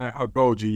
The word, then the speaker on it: Agoge: So, well, before we go, I just wanted to ask, you know, again, a reminder that Coach wrote this book, Agoge, right? I'm pronouncing that right, I think, right? – Agoge, yes.